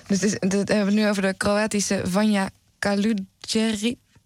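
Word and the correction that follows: Vanja Kaluđerčić: Vanja Kaludjercic. – Kijk, jij kan het beter uitspreken.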